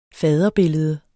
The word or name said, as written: faderbillede